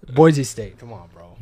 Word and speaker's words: Boise: Boise. That's how people in Boise pronounce it. – That's how you say it. It's Boise.